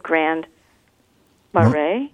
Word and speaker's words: Grand Marais: And just everything about where you are, I have to admit, I never heard of Grand Marais.